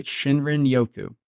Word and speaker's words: Shinrin-yoku: Okay, this word is Shinrin-yoku. – Yep, that sounds pretty good to me. – How would you say it? – I think you're right, Shinrin-yoku.